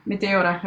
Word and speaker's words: Meteora: It was Materia from Materia. How do you pronounce it? – Was it Meteora? – Yeah, Meteora, I call it, from Linkin Park, and Amazing.